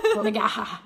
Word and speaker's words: McGaha: Literally, it's M-C-G-A-H-A. – Magaha. – How do you say it? – Magaha.